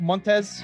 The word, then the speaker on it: Montes: Our next guest coming up on the podcast, Jim Montes, not related to Lotto, but just as exciting. All right, Jim Montes. Is that pronounced right? Montes? – That's it.